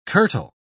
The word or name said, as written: kirtle